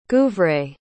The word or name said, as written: guivret